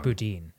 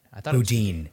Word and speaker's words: Boudin: It's Boudin. – Boudin. – I say Boudin, but I only ever read it.